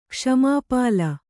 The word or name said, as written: ಕ್ಷ್ಮಾಪಾಲ